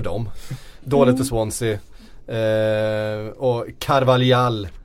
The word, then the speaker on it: Carvalhal: Carvalhal ska det uttalas. Det vi säger ju ofta fel. Vi blandar ihop portugisiska och spanska uttal hela tiden i den här podden.